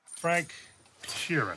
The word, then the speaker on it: Frank Sheeran: Frank Sheeran. Am I saying that right? – Yeah, you said right.